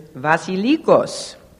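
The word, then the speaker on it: vasilikos (βασιλικός): Now, in the Greek, the word for nobleman is vasilikos. Even though it looks like a B, a B in Greek is pronounced as a V.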